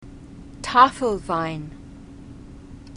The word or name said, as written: tafelwein